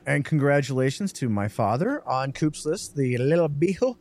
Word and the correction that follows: Le Bijou: Is that how you say it, Jordan? – I think it's Le Bijou. – Le Bijou, 1922.